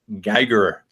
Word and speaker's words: Geiger: And I'm currently in the Kuiper Masters of Ministry program, and I chose the book Simple Church by Tom Rainer and Eric Geiger, I think is how you say his name. – Yeah. Good. Thanks.